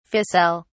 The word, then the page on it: ficelle: British English